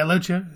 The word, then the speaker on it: Loggia: Whereas this doctor, Dr. Raymond, played by, I'm going to say his name wrong, is it Robert Loggia? – Yeah, Loggia, that's right.